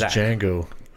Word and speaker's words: Django: X-A-Q. – My name is Django. The D is silent.